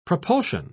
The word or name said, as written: propulsion